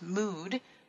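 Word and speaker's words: Möod: As the inventor and founder of Mood, and it's spelled, it looks like mud, but it's got the umlaut, so it's Mood, Mood Modular, Amy Lokken has over 15 years of experience in the design world.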